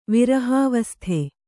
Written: ವಿರಹಾವಸ್ಥೆ

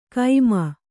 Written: ಕೈಮ

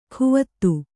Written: ಕುವತ್ತು

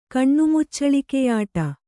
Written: ಕಣ್ಣುಮುಚ್ಚಳಿಕೆಯಾಟ

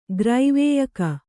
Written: ಗ್ರೈವೇಯಕ

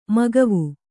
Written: ಮಗವು

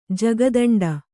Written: ಜಗದಿಂಡೆ

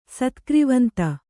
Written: ಸತ್ಕ್ರಿವಂತ

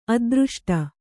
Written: ಅದೃಷ್ಟ

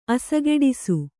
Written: ಅಸಗೆಡಿಸು

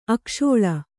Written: ಅಕ್ಕಸಾಲ

